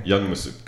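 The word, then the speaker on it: Youngmisuk: How do you say his last name? – Young Masuk. – Young Masuk. I had no idea.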